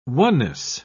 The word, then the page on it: oneness: British English